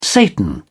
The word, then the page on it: satan: British English